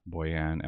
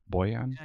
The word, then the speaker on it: Bojan: Bojan, am I pronouncing it right? Bojan? – Yeah, exactly.